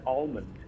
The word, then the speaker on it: almond: I've always said almond. – Almond? – Almond. I pronounce the L. Do you? – Almond. Yes. – Yeah, but the dictionary is saying this. – Almond. Almond. – Ew, that's so weird. – Almond. Almond. – Almond. – No, the dictionary's not even saying the L. – No, it's not. However, it does put it down as an optional pronunciation.